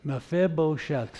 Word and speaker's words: Mephibosheth: His name is Mephibosheth. How about that for a name? Mephibosheth. I wrote it out for you. Let's see if we can pronounce it. Mephibosheth.